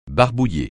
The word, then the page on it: barbouiller: francais